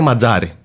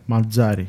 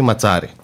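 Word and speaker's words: Mazzarri: Α, και μιας και ανέφερα και το όνομα, να πούμε ότι ο ίδιος του πλέον βάζει τέλος στην λάθος προφορά και την καταγραφή που είχαμε πολλές φορές για το όνομα του. Λέγεται Ματζάρη. – Ματζάρη. – Και όχι Ματσάρη.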